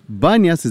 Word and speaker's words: Banias: Banias is a mispronunciation of the Greek word Panias because there's no P in the Arabic alphabet.